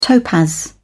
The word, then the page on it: topaz: British English